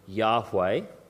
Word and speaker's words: Yahweh: In the original language of Hebrew, it sounds like the word Yahweh, and that's often depicted by four letters, YHWH.